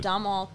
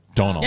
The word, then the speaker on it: Domhnall: Domhnall Gleeson? – Donal.